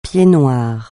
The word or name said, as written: pied-noir